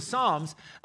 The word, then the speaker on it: Psalms: So I recently had to tell my boys, it's the P is silent, it's like pneumonia, you know? Yeah, like that. Anyway, so the Psalms, and they're beautiful, and they still speak to us, but they give us this window into how David saw the world.